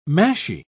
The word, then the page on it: mashie: British English